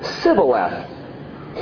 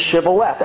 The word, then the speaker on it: Shibboleth: And if he said no, they would say to him, say now, Shibboleth. Say this word, Shibboleth. But an Ephraimite, he said, Sibboleth. for he could not pronounce it correctly.